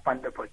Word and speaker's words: Vandenput: So my surname is pronounced Vanderput.